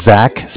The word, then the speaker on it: Zach: So my name is Zach, spelled Z-A-C-H. – Z-A-C-H.